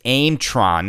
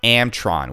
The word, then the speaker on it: Aimtron: And so I also, I guess I was pronouncing it's Aimtron, not Amtron, which is American Pinball, the company that is now acquiring American Pinball.